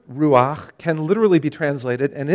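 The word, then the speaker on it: ruach. The (רוח): verse 2 is the Hebrew word ruach. And you have to get that good little guttural sound in there if you want to say it right. Ruach. The phrase in Hebrew is ruach Elohim.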